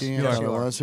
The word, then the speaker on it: Greinke: I'm going against Zach Greinke, who is, am I saying that correctly? – Yes, Greinke, who is really good.